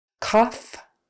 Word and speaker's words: cough: The next way it can be pronounced is off. So just like the last one, only this time the GH spelling is kind of turning into an F sound. So it's kind of like O-F-F or off, A-H-F, however you want to spell it, off. Because obviously O-U-G-H makes no sense. So cough, coughing, and trough.